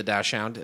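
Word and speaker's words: dachshund: While most dogs are content to cover, at most a couple of thousand feet on a walk, Riley, the Dashhound, am I saying that right? – Yeah.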